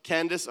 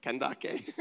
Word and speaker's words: Kandake: And there was an Ethiopian, a eunuch, a court official of Candace," or how do you say it? Kandake.